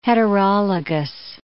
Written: heterologous

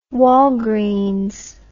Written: walgreens

